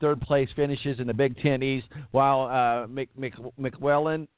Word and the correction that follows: McElwain: is that how you say his name? Is 19-8 with a bowl McElwain. – Win. – McElwain.